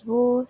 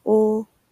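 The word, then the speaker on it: o (を): It's almost never used since the particles are always written in hiragana and the wo character.Even though it's pronounced as wo, sounds like o in real speech.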